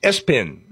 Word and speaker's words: ESPN: Number three, the name was ESPN. Pronounced Espin.